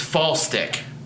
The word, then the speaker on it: Falstich: Cheer, I told you it's Falstich. – That's not how it's spelled. – Well, BJ yelled at us. – So it's spelled Falstich, though. – It's like false dick, like it's like a fake dick, you know, like a sex toy.